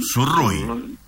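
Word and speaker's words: Sourrouille: Sourougui. Sí. Así era el apellido, Sourougui, si no me equivoco. – Sí, ¿mm? Sí, se pronuncia así.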